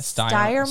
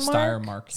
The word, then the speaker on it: Steiermark: And then we have Steiermark. Am I saying that right? – Let me see how it's spelled. Yeah, Steiermark. – Steiermark.